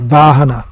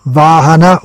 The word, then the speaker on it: vahana: That actually comes from Sanskrit, vahana. Where the v is pronounced as w, and the h is pronounced as g. Still in Tamil, h and g are interchangeable. So, vahana, wagon.